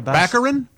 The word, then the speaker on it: Baccarin: Daredevil's girlfriend, Morena Baccarin. – Baccarin.